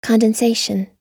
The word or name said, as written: condensation